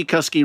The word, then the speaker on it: Cusky: First of all, from Angie Cusky Rhymes with Husky, who won our poetry competition at the end of last year and got a W.H.